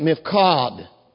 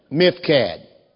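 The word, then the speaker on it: Miphkad: Some Hebrews pronounce it Mifkad, but in our English pronunciation we call it Mifkad. You ought to learn to say that word, Mifkad.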